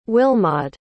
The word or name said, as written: Wilmod